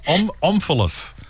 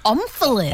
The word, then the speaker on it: omphalos: Omphalos. – Omphalos. – Omphalos. – Omphalos? – O-M. – Is it double L? – O-M-P-H-A-L-O-S.